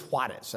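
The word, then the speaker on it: Juárez: It's actually referred to as Juarez. That's how you say it in Spanish.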